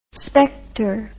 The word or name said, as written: spector